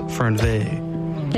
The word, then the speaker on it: Fernweh: Okay, I think this is French because it's spelled Fernweh, but it's pronounced Fernveh.